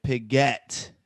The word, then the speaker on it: Piguet: Piguet. It's like you're saying Piguet. Like you're emphasizing the get part.